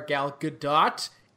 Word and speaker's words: Gadot: For now, for the uninitiated, this does star Gal Gadot. And yes, we did look it up. And yes, that is roughly how it's pronounced. The T is not silent.